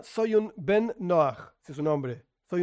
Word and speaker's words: Noach: Yo, por mi pronunciación, suelo decir más bien Noach, pero es lo mismo, Noach, Noach, tiene que ver con la pronunciación del hebreo del mundo ashkenazi, del mundo sefaradí.